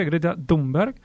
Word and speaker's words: Greta Thunberg: Así se pronuncia, Greta Thunberg.